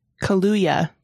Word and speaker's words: Kaluuya: Kaluuya. – Kaluuya. Not Kaluuya.